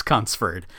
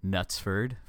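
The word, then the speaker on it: Knutsford: I would first like to apologize to the people of Nutsford, for that is how, in fact, it is pronounced.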